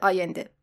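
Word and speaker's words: Allende: البته توی پرانتز یه چیز رو بگم، ما در ایران اسم ایشون رو به صورت سالواتور آلنده تلفظ میکنیم که البته اشتباهه. تلفظ درست هست سالواتور آینده.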